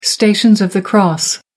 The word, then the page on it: stations of the cross: American English